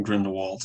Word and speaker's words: Grindelwald: Hoe zou het uitspreken? Op zijn Duits? – gewoon, gewoon Grindel, Grindelwald.